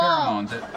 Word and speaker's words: pheromones: Pheromones.